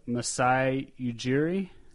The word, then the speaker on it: Masai Ujiri: And they also had the Executive of the Year, Masai Ujiri. Did I say that correctly? – I think you did.